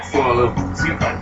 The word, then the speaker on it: Simu Liu: Simu Liu? – It's basically like Simu Liu.